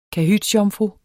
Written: kahytsjomfru

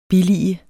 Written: BILLIGET

